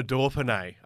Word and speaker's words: Dauphiné: Dauphiné. – Well done. – How's that? Not bad. – There's no R in Dauphiné, but I'll take Dauphiné.